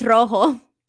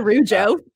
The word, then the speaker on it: rojo: Wait, no, it's rojo. – Rojo. – Not rujo.